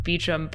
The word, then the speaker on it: Beauchamp: I know that it's sometimes pronounced Beecham, but it's spelled like Beauchamp. But I don't remember which way they say it. – They say Beauchamp, I believe, because I'm like, he doesn't look French.